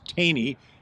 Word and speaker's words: Thoene: Anybody that can see the spelling of T-H-O-E-N-E as our last name and recognize Taney as the pronunciation may have guessed that I'm the researcher, the historian in the writing team of Bodie and Brock Taney.